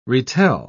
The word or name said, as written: retell